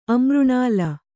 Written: ಅಮೃಣಾಳ

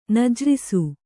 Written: ನಜ್ರಿಸು

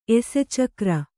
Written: ಎಸೆಚಕ್ರ

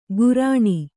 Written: ಕುರ್ಣಿ

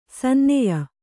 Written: ಸನ್ನಾಯ್ಯ

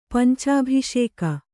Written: ಪಂಚಾಭಿಷೇಕ

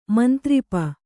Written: ಮಂತ್ರಿಪ

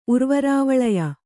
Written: ಉರ್ವರಾವಳಯ

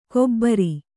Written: ಕೊಬರಿ

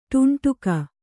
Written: ಟುಂಟುಕ

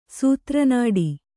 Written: ಸೂತ್ರನಾಡಿ

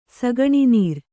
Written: ಸಗಣಿನೀರು

